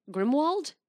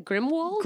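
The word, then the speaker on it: Grimmauld: How do you say it? – Grimald. – You're saying Grimwald? – Grimald. Yeah, Grimald. – I thought you said Grimwald. – No, Grimald.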